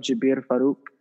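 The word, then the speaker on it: Jabir Farooq: my SoundCloud is, you can look up Jabir Farouk. I'm leaving that project under that name. To spell it is J-A-B-I-R and space F-A-R-O-O-Q.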